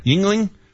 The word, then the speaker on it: Yuengling: That allowed Yuengling, is that how you pronounce it? – Yuengling, to now qualify as a craft brewery.